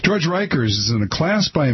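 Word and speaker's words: Rekers: This is about a guy named, and I'm not even sure how to pronounce his last name. It's R-E-K-E-R-S. – I'd say Reekers. – I'm going to say Reekers. George Reekers, according to Frank Rich in the New York Times yesterday, now 5 seconds into Monday we are.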